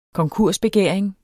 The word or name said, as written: konkursbegæring